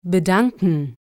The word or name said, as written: bedanken